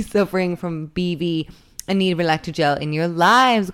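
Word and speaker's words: Relactagel: So we are sponsored this week by Relectagel. Am I saying it right? – You are.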